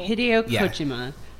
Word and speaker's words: Hideo Kojima: His name is Hideo Kojima. Am I saying that right? – Yes. – Oh. – Hideo Kojima.